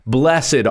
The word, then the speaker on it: blessed: it's two syllables blessed are rather than blessed are but as familiar as the beatitudes are they are also very misunderstood and misrepresented.